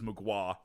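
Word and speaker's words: Mgła: Oh, Magua. Okay. – It's how he pronounces Magua like that, but it's MGLA is how you would spell it.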